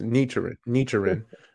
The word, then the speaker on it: Nichiren: Is that how you pronounce So you're on track. Yes, it's Nichiren. So yeah, you phonetically sounded it out, which is what puts you on the right course for sure.